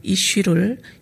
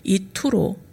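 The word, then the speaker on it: 이슈 (אִישׁ): 갈릴리 지방의 독특한 방언을 사용했을 베드로는 내가 그 사람을 이라고 말할 때 사람을 의미하는 히브리어 이슈를 이투로 발음하였을 것입니다. 그래서 사람들은 이런 특이한 발음을 지적하며 베드로를 예수와 한 무리로 간주하였습니다.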